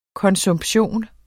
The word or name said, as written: konsumption